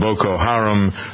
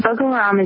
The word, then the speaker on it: Boko Haram: People have been mispronouncing it, called Boko Haram, kind of like the old singing group, Procol Harum. That's not the way to pronounce the name.